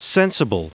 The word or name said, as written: sensible